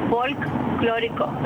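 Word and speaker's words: folklórico: It's folklorico, like folklore. So the L is before and after the K.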